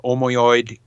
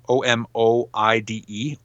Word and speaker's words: Omoide: One called Omoide in My Head, and that's spelled O M O I D E, Omoide in My Head, and another song called Tattoo.